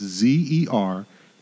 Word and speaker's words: Zerkalitsa: And I think I'm pronouncing it right. It's Zerkalitsa. Sometimes pronounced with a D in front of it.